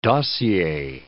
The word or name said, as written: dossier